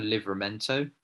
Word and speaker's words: Livramento: I actually went for Livramento. Is that how you pronounce it at Southampton? – Yeah.